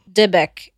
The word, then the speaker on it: Dybbuk: The word Dybbuk, I think I'm saying it right still. I said it right the first time. – Dybbuk.